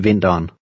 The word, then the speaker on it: vinteren: In colloquial speech, the last word is pronounced with just two syllables. Try to read number 14. – Sonja kan bedst lide vinteren.